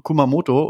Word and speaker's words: Kumamoto: im südwestlichen Präfektur hier Kamamoto, äh, Kuma, Kumamoto und äh,